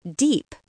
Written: deep

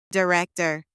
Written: director

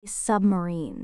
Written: submarine

